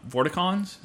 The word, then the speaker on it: Vorticons: And his foes were the Vorticons? Is that how you pronounce it? – Yeah, and in the first game, he would have to collect pieces of this rocket that was made from household items.